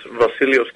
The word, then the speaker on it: Vasileios: Well, my full name is Vasileios Kalogirou,